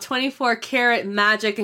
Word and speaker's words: 24K Magic: It's called 24 Karat Magic, in case you're wondering how to pronounce it, since it's written 24K Magic.